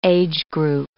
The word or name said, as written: age-group